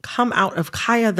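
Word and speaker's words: Khia: I am going to, in this conversation, pronounce Kaya, the rapper, with the long I.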